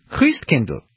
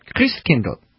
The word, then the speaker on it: Christkindl: Now, how do you say Christ child in German, which of course was Martin Luther's language? Christkindl. That's right, Christkindl. What does that sound like? Kris Kringle. That's where the name comes from. The German pronunciation of Christ child, Christkindl, Kris Kringle.